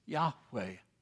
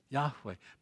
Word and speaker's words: Yahweh: In the Old Testament, his name is given us Yahweh. Yahweh. Because of the influence of the German language on our English language, you have perhaps heard his name pronounced Jehovah.